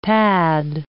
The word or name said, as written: pad